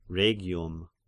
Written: Rhegium

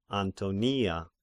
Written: Antonia

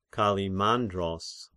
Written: Kallimandros

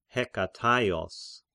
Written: Hekataios

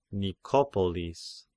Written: Nikopolis

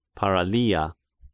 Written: Paralia

